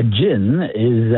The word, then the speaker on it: jinni: A jinn is a spiritual being. We know them most commonly as genies, like Aladdin and his genie from the lamp. Genie is actually just mispronounced in Arabic. It's pronounced jinni, which is a singular, powerful jinn.